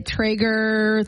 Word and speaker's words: Traeger: You could win a Traeger 34 Pro Pellet Grill.